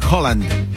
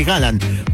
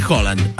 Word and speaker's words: Haaland: Bocinia y Haaland son... Perdón, Haaland no, hay que empezar a decirle Haaland. Porque en noruego la doble A se pronuncia como una O.